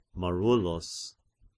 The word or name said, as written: Marullus